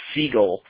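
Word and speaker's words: Segall: I believe his name is pronounced Segal, but it's S-E-G-A-L-L.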